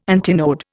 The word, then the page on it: antinode: Indian